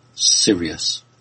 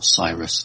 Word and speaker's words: Sirius: Sirius, or Cyrus, depending how you want to pronounce it.